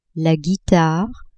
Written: guitare

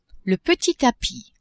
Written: petit tapis

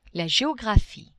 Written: geographie

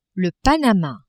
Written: Panama

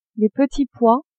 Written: petits pois

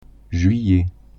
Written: juillet

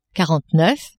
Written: quarante-neuf

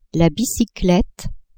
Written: bicyclette

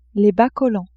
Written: bas-collants

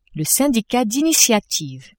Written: syndicat d'initiative

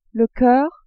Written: coeur

